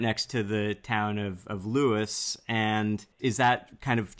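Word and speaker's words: Lewes: And that's a very common thing that people pronounce it right, but it's Lewis, just like a person's name.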